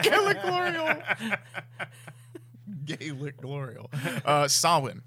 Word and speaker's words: Samhain: It's spelt Samhain. It's pronounced Samhain.